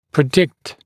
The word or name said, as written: predict